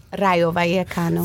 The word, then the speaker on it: Rayo Vallecano: Rayo Vallecano.